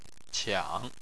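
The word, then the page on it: qiang: tone 3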